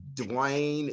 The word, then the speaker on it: Dwane: Dwayne is spelled D-W-A-N-E, without a Y and without a U.